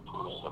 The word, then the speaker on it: Pulitzer: Pulitzer Prize.